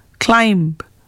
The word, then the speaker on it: climb: In the words that you are going to hear now, the letter B is silent when it appears with M, like womb, tomb, bomb, climb, comb.